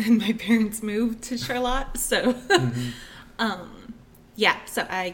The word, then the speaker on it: Charlotte: Yes, Charlotte, not Charlotte. But. – How do you spell it? – It's spelled exactly like Charlotte, but like the founder's wife, it's named after her, and they pronounced it Charlotte, so that is how it's pronounced.